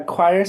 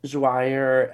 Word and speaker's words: XOIR: yeah, choir spelled with an X, XOIR. Some people say joir, X choir.